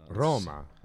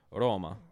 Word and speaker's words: Roma: Roma, say it right. – Roma. – Correct. Yeah.